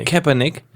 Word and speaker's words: Kaepernick: Kaepernick.